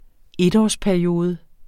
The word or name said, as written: etårsperiode